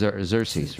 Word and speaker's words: Xerces: I'll have to spell it for you. It's X E-R-C-E-S. And I think it's pronounced Xerces, right? – Xerces.